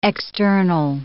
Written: external

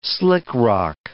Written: slickrock

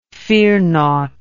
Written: fearnought

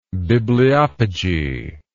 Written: bibliopegistical

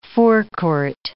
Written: forecourt

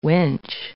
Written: winch

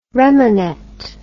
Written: remanet

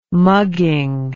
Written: mugging

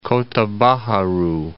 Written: Kota Baharu

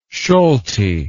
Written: Solti

